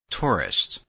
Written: tourist

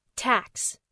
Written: tax

